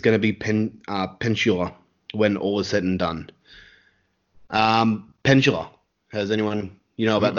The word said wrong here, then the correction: Peninsula: Am I pronouncing it wrong? – You're probably pronouncing it fine. – Yeah, I'll say Peninsula. Yeah. – Peninsula. What am I saying? Pendula.